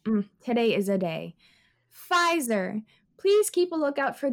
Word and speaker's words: Pfizer: Wait, the P is silent? Yes, that's why it's called Pfizer. I thought it was called Pfizer. – No, I'm... take out the P.